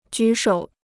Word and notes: 举手: jǔ shǒu

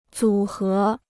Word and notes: 组合: zǔ hé

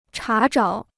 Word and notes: 查找: chá zhǎo

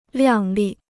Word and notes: 亮丽: liàng lì

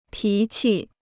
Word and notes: 脾气: pí qi

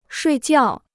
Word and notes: 睡觉: shuì jiào

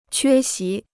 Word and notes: 缺席: quē xí

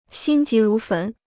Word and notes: 心急如焚: xīn jí rú fén

